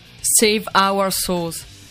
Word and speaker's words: Save Our Souls: Save Our Souls. – Perfetto, quindi proprio la pronuncia è quella giusta, ok.